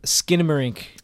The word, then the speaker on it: Skinamarink: This week we have Skinamarink to talk about. I think I said that right. – That's right. Yep. – Skinamarink.